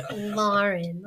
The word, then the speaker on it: Lauren: And so I say it the New England way, Lauren, like laundry, because I always point out there's no O in my name.